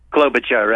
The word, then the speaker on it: Klobuchar: Okay, I may mispronounce this. Amy Klobuchar Booker. – Klobuchar, right? – Right, yeah, thank you.